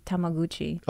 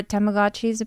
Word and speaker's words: Tamagotchi: Is how you say it? I used to say Tamaguchi. – Oh, really? Tamaguchi? – Tamagotchi.